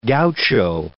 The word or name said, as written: gaucho